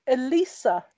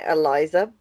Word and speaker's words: Elisa: So today, today we are discussing Elisa Lam and the Cecil Hotel. To explain, we've been pronouncing it Eliza, but we've come to the conclusion it's probably Elisa. So it's Elisa.